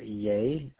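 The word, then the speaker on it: Yeh: Her name is Julie Ye. I believe it's how it's pronounced. It's Y-E-H.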